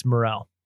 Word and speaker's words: Merrell: I think it's Merrell. – Is it Merrell? Sorry. Yeah. Merrell. I was trying to phonetically pronounce it. Did I not? Merrell. Okay.